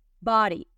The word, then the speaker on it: body: Y en lugar de decir body, se suaviza y suena como una R body, body. Esta es la pronunciación oficial del el diccionario en los Estados Unidos. Body, body. Yo sueno como ella, pero menos, menos robótica. Body, body. Entonces, ¿qué pasa? La D se suaviza.